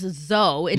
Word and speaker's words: Zoe: Zoe. – Zoe, yes. – Or it can also be pronounced Zoe. It is a Greek word.